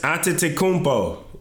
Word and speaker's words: Antetokounmpo: For Steph Curry's team is James Harden, DeMar DeRozan, Giannis Antetokounmpo. You like that? You like that pronunciation? – There you go.